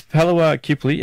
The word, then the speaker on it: palawa kipli: So that's Palawa Kipli, and I'm pronouncing that correctly.